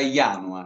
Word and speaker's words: Janua: Per quel che riguarda il mio nome si pronuncia Ianua, eh. si scrive con la J ma si pronuncia con la I.